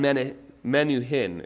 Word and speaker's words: Menuhin: A man once heard the world famous violinist Yehudi Menahuni, play beautiful music. After the concert, he tracked down Menuhin, is how it's pronounced, and he said, I would give my life to play like that.